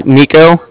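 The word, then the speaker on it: Niko: All right, my name's Nico. That's N-I-K-O. I'm calling from the Bank of Cardiff. That's spelled C-A-R-D-I-F-F, as in Frank Frank.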